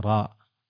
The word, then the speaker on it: راء: مثل ذلك في القرآن، مثل حرف الراء، الله يقول ألف لام راء، فننطقها هكذا راء، ألف لام راء. وصاد في القرآن نقول باسمها صاد، والقرآن ولا نقول بصوتها صاء.